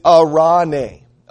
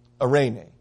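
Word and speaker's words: arane (εἰρήνη): In the Greek, in the New Testament, it's not shalom, it's ara. I'm going to make sure I try to say this right. It is arane. Arene.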